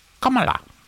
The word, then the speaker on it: Kamala: It's actually pronounced Kamala. Thanks for the lesson, Lib.